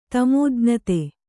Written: ತಮೋಜ್ಞತೆ

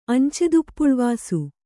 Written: ಅಂಚೆದುಪ್ಪುಳುವಾಸು

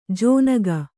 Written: ಜೋನಗ

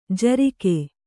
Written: ಝುರುಕಿ

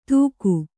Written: ದೂಗು

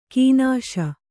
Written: ಕೀನಾಶ

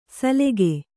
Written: ಸಲೆಗೆ

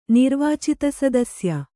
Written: ನಿರ್ವಾಚಿತಸದಸ್ಯ

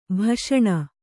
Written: ಭಾಷಣ

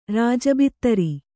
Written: ರಾಜಬಿತ್ತರಿ

